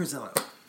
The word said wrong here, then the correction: Verlizzo: No, said it wrong. Brunch. Frank Verlizzo.